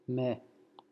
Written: me

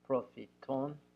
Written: profiton

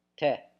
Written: te

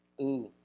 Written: ou

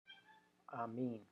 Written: Amin

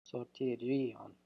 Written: swrtirian

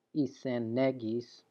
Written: isenegis

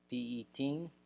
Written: Pieteen

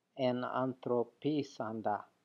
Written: enanthropisanda

